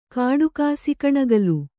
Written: ಕಾಡಕಾಸಿ ಕಣಿಗಲು

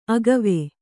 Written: ಅಗುವು

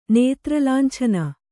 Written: ನೇತ್ರಲಾಂಛನ

